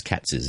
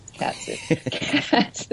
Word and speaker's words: Katz's: Yeah, and you say it correctly, too. – How so? – Well, some people say Katz with a silent thingy, but it's Katz's, isn't it? That's the correct one. – Yes, Katz's.